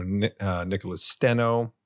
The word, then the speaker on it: Nicholas Steno: So Nicholas Steno would be how I would pronounce it.